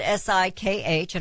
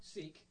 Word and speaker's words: Sikh: S I K H. I don't want to be offensive. Father wore a turban. Cause he, huh? Sikh. Sikh. So I was right. Sikh, like sheik.